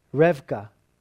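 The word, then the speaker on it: Rivka: And if you were to say it properly, it'd be it's more like Revka, or Revka is how you say her name.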